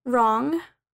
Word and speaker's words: wrong: The silent W. The W isn't pronounced at the beginning of a word when it comes before the letter R. Wrong, rap, right, wreck, wrist, wrestle. For example, shall I wrap it for you?